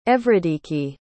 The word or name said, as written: Evridiki